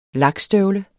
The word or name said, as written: lakstøvle